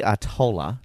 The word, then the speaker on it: Artola: But her name, to clarify it, is Vicky Artola.